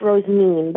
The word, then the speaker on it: Rosamine: My name is it's spelled Rosamine. It's pronounced Rosamine, but I go by Rose.